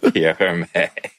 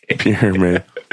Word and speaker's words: Hermé: It's just Pierre Hermé. – Pierre Hermé.